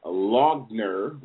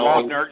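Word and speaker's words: Loughner: Well, Jared, right now, Jared Lee, and his last name is pronounced Logner, which is L. – Logner, that's correct.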